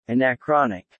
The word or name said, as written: anachronic